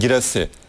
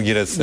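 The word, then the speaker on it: Guirassy: Vedat Muriç ve Giysar'ın Vedat Muriç ve Dortmund'daki oyuncunun tam doğru telaffuzu. – Girassi. – Girassi ve Serhu Girassi, evet.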